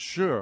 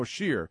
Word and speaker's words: Shire: Shire is pronounced sure or sheer. Depending on the country or your accent, the only Shire is the Lord of the Rings.